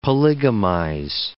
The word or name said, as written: polygamize